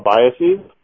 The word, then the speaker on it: biases: I read something that you're also interested in, and I even can't pronounce it, cognitive diabases. Is it right? – Oh, biases? – And yeah. – Biases, yeah.